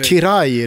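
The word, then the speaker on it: Király: När eh, Eh, Gabor Kirali, hur han nu uttalar sig. – Kirai är det ju många som kör.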